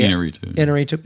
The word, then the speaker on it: Iñárritu: Like, it's Alejandro Iñárritu, if I'm pronouncing that correctly. – Iñárritu.